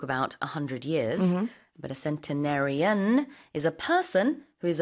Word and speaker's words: centenarian: It's centenarians. It's spelled C-E-N-T-E-N-A-R-I-A-N. – Centenarian, like century, Like century, yes.